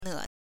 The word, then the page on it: 呢: ne